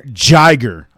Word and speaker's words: Jiger: It is spelled J-I-G-E-R. So we're going to say Jiger. I say it again, Jiger.